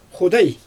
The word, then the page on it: xudɛj: Xyzyl